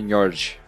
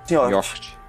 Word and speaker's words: Njord: Acho que é Njord, tipo Mjolnir. Njord.